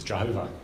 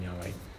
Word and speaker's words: Yahweh: So you kind of have to, we've kind of guessed really at the pronunciation Yahweh, which is why you have Jehovah's Witnesses. Jehovah was an old guess at how you pronounce God's name.